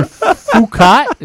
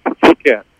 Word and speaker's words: Phuket: It's Phuket. – Phuket. – And you pronounce it very, very delicately.